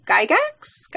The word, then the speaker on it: Gygax: came out of a meeting between Gary Gygax.